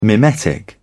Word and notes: mimetic: British English

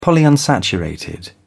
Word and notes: polyunsaturated: British English

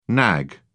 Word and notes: nag: British English

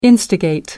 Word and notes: instigate: American English

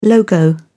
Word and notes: logo: British English

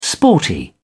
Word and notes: sporty: British English

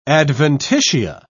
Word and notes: adventitia: British English

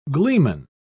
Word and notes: gleeman: British English

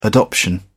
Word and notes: adoption: British English

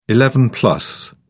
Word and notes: eleven-plus: British English

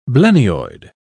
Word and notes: blennioid: British English

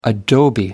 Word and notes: adobe: American English

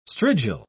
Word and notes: strigil: British English

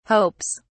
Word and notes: hopes: British English

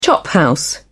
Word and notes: chophouse: British English

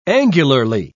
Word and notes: angularly: British English

